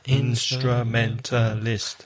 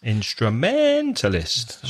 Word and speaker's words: instrumentalist: Instrumentalist. – 5 syllables. – Instrumentalist. Stress the middle one.